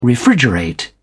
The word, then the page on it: refrigerate: British English